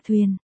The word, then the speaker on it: thiền: Em gái của Tô Đông Pha, chính, là thiền, ta quen đọc là thuyền.